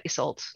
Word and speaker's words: Isolt: Yeah, I'd pronounce the T a little bit, Isolt.